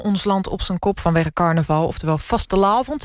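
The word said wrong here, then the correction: vastelaovend: zeg ik dat goed, Stephanie? Die komt uit Maastricht. – Nee, het is vastelavond.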